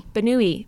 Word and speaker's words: Bernoulli: Am I pronouncing that right? Do you know? Bernoulli. – Bernoulli. Bernoulli numbers.